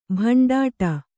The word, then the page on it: ಭಂಡಾಟ: kannada